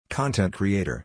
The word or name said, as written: Influencer